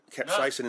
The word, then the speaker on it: capsaicin: And capsaicin, which is the am I saying that right? – Capsaicin. It's the active ingredient.